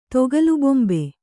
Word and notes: ತೊಗಲುಬೊಂಬೆ: Kannada

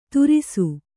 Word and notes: ತರಾಸು: Kannada

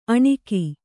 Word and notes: ಅಣುಗಿ: kannada

